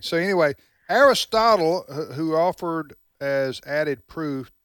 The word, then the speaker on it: Aristotle: Aristotle. – No, Aristotle, that's right.